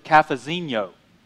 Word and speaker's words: Cafezinho: And by the way, I was told to pronounce it Cafezinho. It's Portuguese.